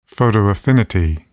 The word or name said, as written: photoaffinity